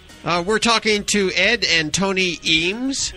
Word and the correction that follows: Eames: No, it's pronounced Ames. – Oh, I'm sorry. Very good. Ames, like as in Ames, Iowa? – Yes. – Right. – But not spelled that way.